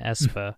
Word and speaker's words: Aespa: Aespa, official pronunciation Aespa.